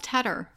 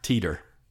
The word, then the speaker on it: Tedder: How do you mispronounce Tedder? – Teeter. – What? – Yeah. – Maybe if it was T-T. Or so, no, T-E-E. – So I just say, it's like the cheese, cheddar, but with a T on it, just so they remember.